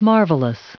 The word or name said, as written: marvellous